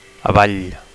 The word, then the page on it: avall: Catalan